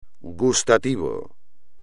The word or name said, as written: gustativo